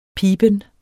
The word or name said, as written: piben